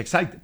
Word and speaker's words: Exide: Dentro se escribe Exide, se pronuncia Exide.